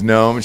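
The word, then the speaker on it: Noem: Christy Gnome, yeah. – Christy Gnome. Like the little... – N-O-E-M, I believe is how you say it.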